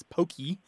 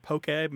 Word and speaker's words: poke: And now the Hawaiian word we're talking about here, to go back to the fish dish, is P-O-K-E, pronounced basically is poke, although in the mouths of Anglophones, it usually comes out as pokey or poke. I have seen it have an accent on the E, but that is incorrect. I think people are being influenced by Pokemon, but poke.